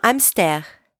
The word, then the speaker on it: hamster: By the way, the H is silent in French. So even if it's spelled the same way, hamster is pronounced without the H.